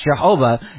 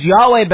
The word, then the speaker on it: Yahweh: Now, linguists today universally agree that the divine name was never intended to be pronounced Jehovah, as Yahweh best reflects the Hebrew consonants and vowels given to Moses.